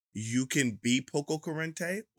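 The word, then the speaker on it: poco curante: How many syllables is that? Poco corriente. It's 5 syllables, ladies and gentlemen. It's poco corriente, and it's an adjective.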